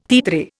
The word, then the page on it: titre: Indian